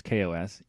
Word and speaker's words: KaOS: And I just want to point out that it is pronounced KaOS, even though I kind of want it to be chaos. That just, I don't know, seems fun, but it is KaOS, so.